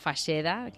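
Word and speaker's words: Fageda: Es La Faxeda, que lo volvemos a decir, que se escribe Faxeda. Corrígeme si me equivoco. – Sí, mira, se escribe Faxeda, una G, una D y una A, que sí.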